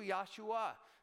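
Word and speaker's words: Yahshua (ישוע): That name, Jesus, is actually in Hebrew, Yahshua, or Yahshua is the way that we would pronounce that in Hebrew.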